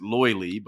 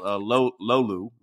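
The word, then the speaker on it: löyly: In other areas, it's a lot of Americans pronounce it loily, but I believe it's called lolu, right? – Lolu.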